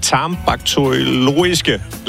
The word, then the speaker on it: tarmbakteriologiske: Du er afsnitleder af det tarmbakteriologiske laboratorium på Statens Serum Institut. – Tarmbakteriologiske? Yes, det er fuldstændig korrekt. Okay. Godt udtalt.